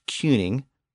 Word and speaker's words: cyning: Again, the original Old English version was spelled C Y N I N G, pronounced Cuning, but that sound eventually shifted to the I sound of king. So by the end of the Old English period, the letter Y and the letter I were representing the same vowel sound.